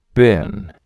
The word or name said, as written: bin